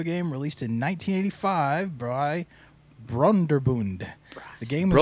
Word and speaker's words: Broderbund: Broderbund.